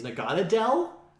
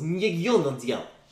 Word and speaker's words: Naganadel: Naganadel through the sewers to the center. – That's how you say its name.